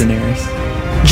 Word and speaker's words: Daenerys: Daenerys.